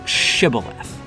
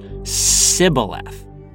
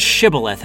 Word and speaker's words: Shibboleth: So when the Gileadites pointed to the river, the Shibboleth, and asked what it was, the Ephraimites called it a Sibboleth. The consequences for this blunder were not pretty. As the book of Judges says, whenever the Gileadites caught someone butchering the pronunciation, they took him and slew him.